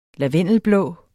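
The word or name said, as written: LAVENDELBLÅ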